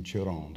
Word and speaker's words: Chiron: You said Chiron and then Chiron, did I say it right? – Mm-hmm.